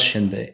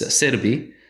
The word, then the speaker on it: Xianbei: Again, the original pronunciation of that name is not Xianbei, it's Serbi. And uh, the Serbi are a Mongolic people who inhabited eastern Mongolia, and uh, they drive out the Huns from central Mongolia.